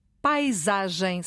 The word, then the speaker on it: paisagem: Paisagem, o A é aberto. Paisagem, a primeira sílaba, tem o A aberto.